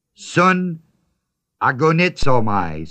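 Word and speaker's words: sun agonizomai (συναγωνίζομαι): Those words, strive together with me, is a Greek word, sun agonizomai, spelled S-U-N, A-G, long O, N, I-Z-O-M-A-I. Sun agonizomai.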